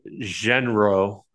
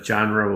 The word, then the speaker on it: genre: And then, um, so you you had given me a hard time about uh, that I pronounced genre genro, I believe.